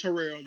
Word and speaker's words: Harrell: Andre Harrell. Is it Harrell? Harrell? – It's Harrell. – Harrell.